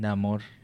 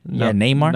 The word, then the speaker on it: Namor: Namor. That's how you say it. – Namor. Oh, okay. – Yeah, Namor. But in Espanol, Namor. – I was about to say the soccer player Neymar, but. – Niño sin amor. Neymar? – Namor. Yeah, I was going to say Neymar, but that was wrong. Namor? – Sounds like that. Just take the Y out.